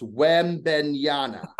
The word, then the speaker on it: Wembanyama: Because you're saying it wrong. It's Wembanyama.